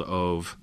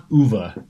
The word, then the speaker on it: Ove: I haven't read the other novel that he's, I think, more famous for is A Man Called Ove. Have you read that one? – Oh, I think it's pronounced Uva because okay. it's Swedish.